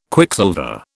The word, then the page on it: Quicksilver: British English